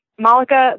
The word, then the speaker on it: Malika: Malika, the way that I pronounce it, is actually not the real pronunciation of my name. It's Malika in Arabic, but my mom, who's Filipino, couldn't pronounce Malika, so she pronounced it Malika, and that's how I pronounce my name.